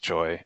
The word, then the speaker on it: Choi: But you know, it looks like Choi is pronounced like that. Choi is Choi. – pronounced Choi. I guess it's one of the most common Korean last names, and at least one spot that I'm looking says that it's pronounced like the word choice without the CE at the end.